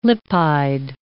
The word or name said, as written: lipide